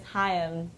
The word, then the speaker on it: Haim: Hey, how you pronounce our last name is Haim. Two syllables.